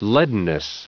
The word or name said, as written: leadenness